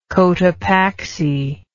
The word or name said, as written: Cotopaxi